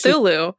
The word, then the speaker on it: Cthulhu: Lovecraft's short story, The Call of, I'm going to butcher how to say it's this. It's Cthulhu. – Cthulhu, thank you.